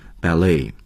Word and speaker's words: ballet: 首先呢，我想成为一名 ballet dancer。那大家知道啊、芭蕾舞啊，读成 ballet，它的重音是靠后的 ballet。I often imagined myself on the stage at the opera house.With the audience cheering at the end of the show.